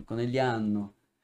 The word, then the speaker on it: Conegliano: Ah, tá, entendi que eu não falo o G de Conegliano. Não, italiano acaba, o G acaba sendo quase nunca pronunciado, né? Fica Conegliano. Na verdade, vocês fazem, né? Ainda fica uma sílaba tônica, ainda fica Conegliano, Conegliano.